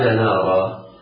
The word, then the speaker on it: نارا: استوقد نارا. نارا الراء في الألف في نارا مرققة، النون والألف مرققة. إياك وأن تفخم النون أو الألف. نارا لا تنطق قطعا نارا، لا، هذا نطق خاطئ.